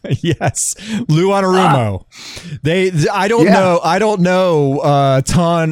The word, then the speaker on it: Anarumo: Speaking of broken, the Colts are hiring former Bengals defensive coordinator Lou Anarumo for their new head coach.